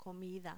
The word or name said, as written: comida